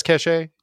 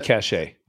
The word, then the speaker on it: Cachet: So My Payroll, they worked with a company called Cachet Financial Services. I think I'm pronouncing it. Do you think that's Cachet? – Yeah, that's totally Cachet. – Cachet. – Yeah. It's Cachet. French.